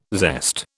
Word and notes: zest: British English

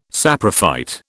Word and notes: saprophyte: British English

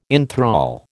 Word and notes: inthrall: American English

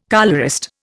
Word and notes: colourist: Indian

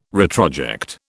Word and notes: retroject: British English

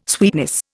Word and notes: sweetness: Indian

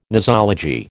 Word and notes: nosology: American English

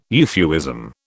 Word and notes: euphuism: British English

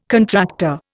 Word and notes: contractor: Indian

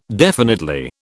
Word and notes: definitely: British English

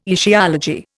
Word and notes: etiology: Indian

